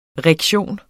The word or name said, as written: rektion